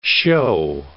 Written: show

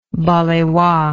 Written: balewa

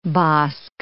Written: bosk

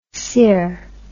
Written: ser

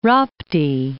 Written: Rapti

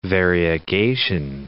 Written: variegation

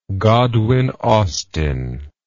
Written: godwin austen

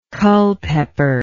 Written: Culpeper

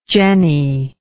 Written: jenney

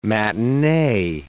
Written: matinee

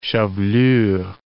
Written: chevelure

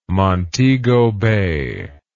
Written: Montego Bay

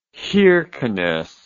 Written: kirkenes

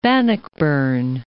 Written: Bannockburn

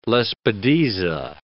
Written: lespedeza

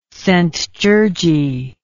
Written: szent-gyorgyi

